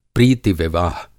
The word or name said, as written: प्रीति-विवाह